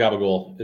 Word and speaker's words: capicola: Capicola, they say that. – Here's gabagool. Gabagool. Oh, that's the same thing? – Yeah, capicola, gabagool. It's gabagool and capicola. It's just the way they pronounce it.